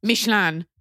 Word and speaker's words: Michelin: Okay, first of all, it's pronounced Michelin, number two, which is funny to me because, like, if there's anything French traveling tire salesmen know, it's good food.